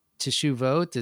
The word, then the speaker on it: teshuvot: Teshuvot, is that how you pronounce that? Yeah, exactly.